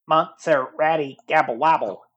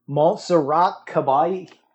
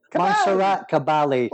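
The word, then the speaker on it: Montserrat Caballé: You'll just get some robot pronouncing Montserrat Gabble Wabble. Montserrat Gabali. – Yeah. Gabali. Montserrat Gabali.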